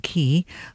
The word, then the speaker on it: Chy: And that is spelt, better spell it for you, it's pretty unusual, C-H-Y, which I'm pronouncing Ki, Rasom, R-A-Z-O-M.